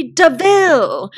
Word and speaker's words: DeVille: So it's funny how Horace says, it's spelled like devil, but it's really DeVille.